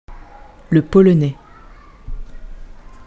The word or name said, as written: le polonais